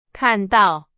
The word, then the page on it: 看到: Chinese